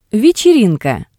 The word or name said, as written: Vetserinka